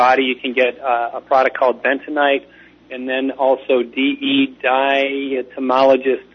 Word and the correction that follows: diatomaceous: earth, I believe I may have said it wrong. – Diatomaceous. – There you go, diatomaceous. I always say it wrong every time.